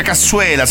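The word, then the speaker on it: cassoeula: Poi altre ricette con la verza e la nostra cassuera. No, non si dice cassuera, lo dici solo tu, si dice cassuela, sei l'unico che lo dice con la R.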